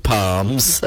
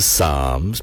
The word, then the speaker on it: Psalms: not the Book of Palms. I know it's got a silent P. It's the Book of Psalms, P-S-A-L-M, Psalm 91, and it reads as follows, if I may read it to you.